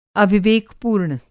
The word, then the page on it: अविवेकपूर्ण: Hindi